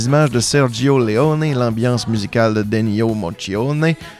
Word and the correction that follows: Morricone: euh, Morricone, Morricone, Ennio Morricone, j'ai toujours de la misère à, le prononcer.